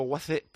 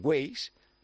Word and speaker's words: Waze: Todos nos enamoramos de su voz habitualmente a través de Google, de GPS, de Waze o Waze, ¿cómo se dice? – Waze. – Yo es que le digo Waze porque soy muy paleto.